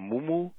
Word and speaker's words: muumuu: A woman came into my office, oh, I guess she was around 45 or 50 years old, and she was wearing a muumuu. Am I saying that right? A muumuu? – Yep, muumuu.